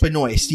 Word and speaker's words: Benoist: Benoist? – Benoist, yeah. – Is it Benoist? – Melissa Benoist.